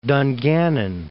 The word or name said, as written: Dungannon